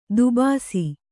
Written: ದೊಬ್ಬಿಸು

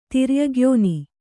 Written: ತಿರ್ಯಗ್ಯೋನಿ

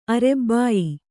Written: ಆರಭ್ಯ

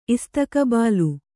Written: ಇಸ್ತಕಬಾಲ

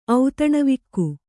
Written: ಔತಣವಿಕ್ಕು

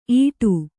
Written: ಈಡು

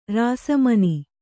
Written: ರಾಸಮನಿ

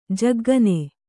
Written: ಝಗ್ಗನೆ

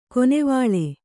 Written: ಖಾನಾವಳಿ

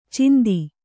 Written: ಚಿಂದಿ

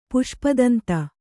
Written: ಪುಷ್ಪದಂತ

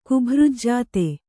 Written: ಕುಭೃಜ್ಜಾತೆ

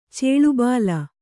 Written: ಚೇಳುಬಾಲ

